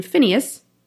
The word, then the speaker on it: Phinehas: Eli had his two sons, Hophni and Phinehas," is that how you say it? – Yes.